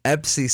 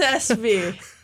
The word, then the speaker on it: ESPY: EPSI, sometimes pronounced ESPY, as well as the other. – Yes, ESPY. – That's the ESPY Awards.